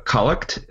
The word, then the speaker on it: collect: Now, one thing worth mentioning is that collect, if you are a first-time listener, is a kind of funny-sounding word. It is actually spelled like collect, as in National Geographic Magazine or Franklin Mint Plates.